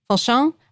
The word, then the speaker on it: Fauchon: Fauchon.